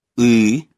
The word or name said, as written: ы